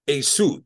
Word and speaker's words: Esau: And with this idea, so in this instance here, this is pronounced Esau. The ayin is pronounced A. And this shin is pronounced sin, sa, and the vav is pronounced with a shurig. So this is a su. So the Latins wrote this as e ye su.